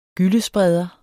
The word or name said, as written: GYLLESPREDER